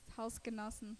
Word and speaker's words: Hausgenossen: Hausgenossen, Haushalt, bisschen altmodisch.